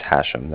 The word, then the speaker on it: Hashem: First name is Hashem. That's H-A-S-H-E-M, as in mother.